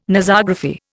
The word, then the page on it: Nosography: Indian